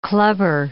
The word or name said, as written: clubber